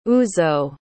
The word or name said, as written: uzo